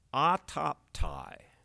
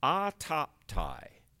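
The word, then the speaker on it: autoptai (αὐτόπται): It, you pronounce it in Greek, autoptai, autoptai.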